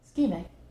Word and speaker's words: ischemic: Ischemic.